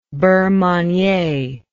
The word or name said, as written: beurre manie